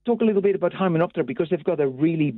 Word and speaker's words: Hymenoptera: It's Hymenoptera. – Hymenoptera.